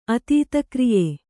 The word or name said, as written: ಅತೀತಕ್ರಿಯೆ